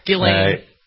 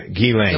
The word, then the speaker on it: Ghislaine: Ghislaine. – Ghislaine. – Ghislaine Maxwell. – The S is silent. The H is silent. And it's like G-E-E-L-A-I-N-E. That's how you should pronounce it.